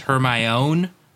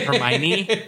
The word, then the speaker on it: Hermione: it's pronounced Hermione, not Hermione.